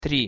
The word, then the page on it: tri: Russian